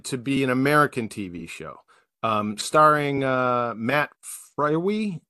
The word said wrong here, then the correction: Frewer: or what, I forget how you pronounce it. – Yeah, that sounds right. Yeah. – And so Frewer, I think. – Yeah. Matt Frewer. Yeah.